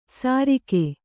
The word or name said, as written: ಸರಿಕೆ